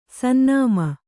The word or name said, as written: ಸನ್ನಾಮಿ